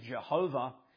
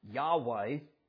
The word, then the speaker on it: Yahweh: This Hebrew word, often referred to in the older translations, Jehovah, and in but more modern scholarship pronounce it as Yahweh.